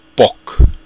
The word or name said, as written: boc